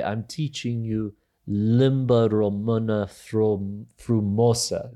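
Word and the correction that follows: frumoasă: Did I say that right? – Frumoasa. – Frumoasa. It's been a long time.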